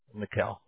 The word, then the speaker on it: Mickael: M-I-C-K-A-E-L. – Mikhail.